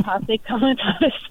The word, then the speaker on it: Posse Comitatus: Now, I know that you have concepts and you have background in an idea called Posse, and I don't know if I'm saying it correctly, Comitatus? – That's right.